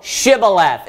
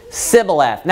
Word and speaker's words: Shibboleth: So they said, "Hey, say, Shibboleth." And then they responded and they said what? Sibboleth.